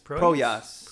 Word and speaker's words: Proyas: I don't know how to say his last I name. You're right. I think it's Poyos. – Is Pro, it Proyas? Proyas. – Proyas. – Proyas. Proyas. Let's Proyas? go with Proyas.